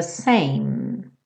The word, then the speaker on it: same: Life and love are the same. Same, terminando o som no M, então não faça same, same.